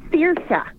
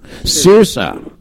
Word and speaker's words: Saoirse: There's an actress of the same name, and I cannot pronounce it to save my soul. It's Saoirse. – It's actually very Saoirse. – Saoirse. Okay. – Saoirse. – Saoirse. – Like S A. Yeah. Saoirse.